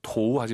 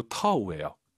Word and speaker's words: 头: 오유는 어우예요. 도우 하지 마시고 터우예요. 이성경성이니까 아하, 头发.